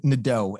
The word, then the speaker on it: Nadeau: So Nadeau, N-A-D-E-A-U. I have the annoyance because nobody pronounces it Nadeau. Everyone pronounces it Nadeau. So I have the annoyance every time I see a word that has E-A-U where people are confidently pronouncing it as O, but for some reason cannot do it with my name. That's my, you know, bureau, chateau, plateau. It's all over. But Nadeau, nobody gets.